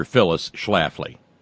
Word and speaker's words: Phyllis Schlafly: You know, so you know, this is the world in which we're living in, that Phyllis Salafi, you know, how the fuck do you pronounce that? – Oh, Phil, you mean, no, you have to say it a certain way. National Treasure Phyllis Schlafly.